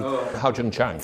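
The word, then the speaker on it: Ha-Joon Chang: Hao-Jun Chang.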